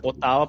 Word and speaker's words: Ottawa: Fala Ottawa pronunciando os dois T's.